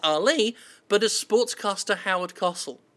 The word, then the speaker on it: Cosell: His name's Howard Cosell. It's pronounced Cosell.